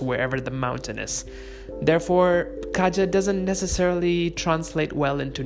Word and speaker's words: Kaja: Again, remember, it might be spelled Kaja, like K-A-J-A, but it's actually pronounced Kaja, because the last A is closed, and it becomes almost like an E.